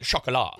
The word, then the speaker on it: chocolat: I only remember because we were making fun of it because you had to pronounce it chocolat. – Yeah. – You know, chocolat. Chocolate in French.